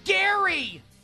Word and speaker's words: Gerry: His name is Jerry with a G. If that wasn't bad enough, it's pronounced Gary. G-E-R-R-Y, pronounced Gary.